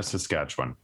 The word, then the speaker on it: Saskatchewan: Saskatoon. Shawana. So I can't even say that. How do you say that? – Regina, Saskatchewan.